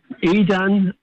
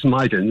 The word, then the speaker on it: Maidan: Is it my Maidan or Maidan? I think it's Maidan.